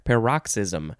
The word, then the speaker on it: paroxysm: Wait, how do you say this word? Paroxysm. P-A-R-O-X-Y-S-M.